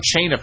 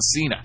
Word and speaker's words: Cena: I was calling him John Chayna for the longest time until somebody finally smartened me up and said, no, idiot, his name is John Cena.